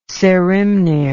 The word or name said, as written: saehrimnir